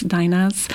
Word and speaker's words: dainas: D-A-I-N-A is how it's spelled. Is that Latvian? – Yes, it is. It is a Latvian term. In Lithuanian, it's dainos. In Latvia, it's dainas.